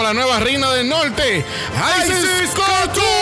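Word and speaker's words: Icesis Couture: ¿Cómo se pronuncia? Isis Couture. Es que está, está escrito diferente.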